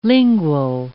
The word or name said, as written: lingual